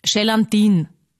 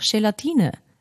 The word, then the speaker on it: Gelatine: Aber es gibt auch Menschen, die sagen zum Beispiel Gelatin. Und ich weiß schon, ich will jetzt auch nicht super gescheit oder obergescheit da daherreden, aber ist halt einfach falsch. Und sagt mir, wenn ich irgendwas falsch ausspreche, aber man kann auch sagen, es ist einfach Gelatine.